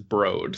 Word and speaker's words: Broad: Yeah, I always thought it was the Broad Museum because that's how it's spelled,